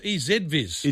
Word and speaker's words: EZVIZ: This is EasyViz. – Yeah, I should have put a pronunciation. – It can't be. It's EZViz. – It's easy. – We say Z.